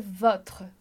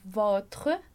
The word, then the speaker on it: votre: E aqui tem um detalhe de pronúncia importante. A gente diz lá pro adjectif possessif, a gente vai dizer votre. E aqui vem o accent circonflexe para fazer com que a gente pronuncie votre. Então, olha lá a diferença. Votre, sem o acento, votre.